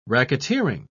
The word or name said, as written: racketeering